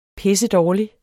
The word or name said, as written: pissedårlig